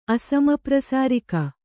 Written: ಅಸಮಪ್ರಸಾರಿಕ

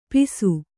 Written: ಪಿಸು

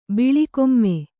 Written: ಬಿಳಿಕೊಮ್ಮೆ

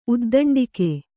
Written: ಉದ್ದಂಡಿಕೆ

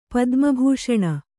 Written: ಪದ್ಮಭೂಷಣ

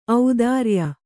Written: ಔದಾರಿಯ

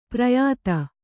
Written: ಪಿರಿಯಾದಿ

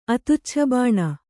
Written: ಅತುಚ್ಛಬಾಣ

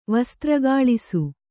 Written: ವಸ್ತ್ರಗಾಳಿಸು

